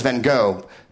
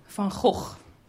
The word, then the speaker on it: Van Gogh: I, uh, they send me emails, and uh, uh, everyone in the world says Van Gogh. You know why? We don't have the temerity. We don't have the fucking cojones to pronounce it the way it's fucking pronounced, which is the way Dutch people pronounce it.